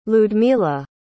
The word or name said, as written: ludmila